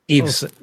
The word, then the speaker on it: Yves: I don't know how to pronounce this name. I always screw it up. But Yves. – Eves. – Yeah, Saint Eves.